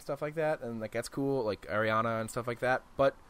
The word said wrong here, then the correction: Aranea: She's Aranea. – Yeah. I knew I pronounced it wrong.